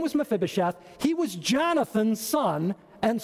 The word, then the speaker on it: Mephibosheth: His name was Mephibosheth. That's how you pronounce that. Mephibosheth.